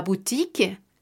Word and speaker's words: boutique: La O y la U juntas o U. Este sonido se hará como una U normal en español. Entonces, algunos ejemplos será la boutique, la boutique, que es la tienda, el A, espacio B-O-U-T-I-Q-U-E, la boutique, que quiere decir la tienda, cualquier tipo de tienda será un boutique, de preferencia alguna pequeña.